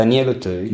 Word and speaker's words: Auteuil: Da- Daniel Auteuil.